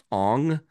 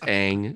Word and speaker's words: Aang: if you're going to have the main character pronounced Ong, you've already failed. So I'm not going to watch your piece of crap movie. It's Aang. It's always been Aang. That's how the show pronounces it.